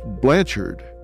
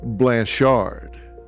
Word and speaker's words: Blancharde: That final E put sort of a classy French patina on it, because now it's not pronounced Blanchard, it's now Blanchard.